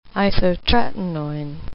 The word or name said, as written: isotretinoin